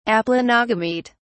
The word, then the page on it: aplanogamete: British English